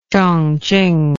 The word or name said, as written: chongqing